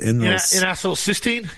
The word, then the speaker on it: N-acetylcysteine: N-acetylcysteine.